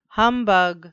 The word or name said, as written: humbug